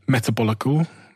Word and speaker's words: Metabolical: And I also have this book called Metabolical.